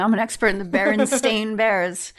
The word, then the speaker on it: Berenstain: It's always been spelled with an A and it's pronounced Berenstain Bears.